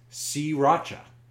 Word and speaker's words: sriracha: The correct pronunciation is sriracha. Most people get hung up on that R that shows up as the second letter.